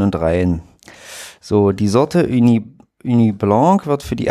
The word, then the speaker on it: Ugni: und die Sorte Uni Blanc U, also UGNI geschrieben. Ich will es jetzt hier nicht komplett falsch. Wie, wie spricht man Wenn das? – UG? UGNI. Ja. Äh, Uni, Uni. Uni Blanc aus der berühmten Cognac-Region.